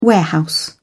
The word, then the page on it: warehouse: British English